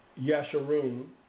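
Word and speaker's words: Jeshurun: It's in your, in the English, it's spelled with a J, but it's not how it's pronounced. Jesharun.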